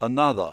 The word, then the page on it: another: British English